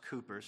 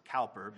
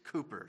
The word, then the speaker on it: Cowper: By the way, it is pronounced Cooper. Some people want to pronounce it Cowper, but it is Cooper.